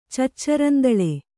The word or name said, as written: ಚಚ್ಚರಂದಳೆ